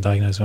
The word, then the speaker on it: diagnose: I love that you do diagnose with a soft S.